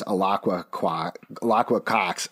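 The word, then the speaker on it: Alaqua Cox: One, mispronounced it. Definitely it's Alakwa Cox.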